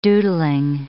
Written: doodling

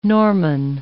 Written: Norman

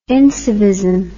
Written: incivism

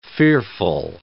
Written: fearful

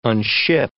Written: unship

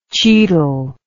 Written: chital

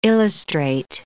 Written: illustrate

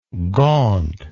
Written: gauntness

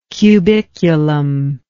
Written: cubiculum

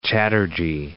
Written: Chatterjee

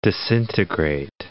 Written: disintegrate

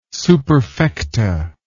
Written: superfecta

